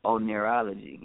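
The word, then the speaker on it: oneirology: It's actually O-N-E, like one, I-R-O-N, like iron, and then ology. – Okay. – You would think it's one iron ology, but it's onirology, right?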